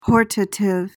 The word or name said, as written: hortative